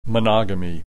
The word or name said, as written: monogamy